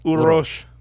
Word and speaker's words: Uroš: Uroš.